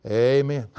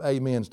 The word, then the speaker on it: amen: The reason I laugh is, you know, there are different kind of amens to us in the South. It's the kind you put the syllable on the first letter, amen. Then there's a kind that puts it on the last syllable, amen.